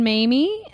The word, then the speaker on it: Mamie: I'm Caitlin Mamie, not Mammy. – Mamie like Jamie.